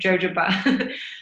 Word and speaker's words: jojoba: That's how you say it, jojoba.